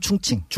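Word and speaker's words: 충칭 (重庆): 네, 중국 충칭. – 발음을 하면 충칭. 충칭.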